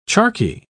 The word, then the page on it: charqui: British English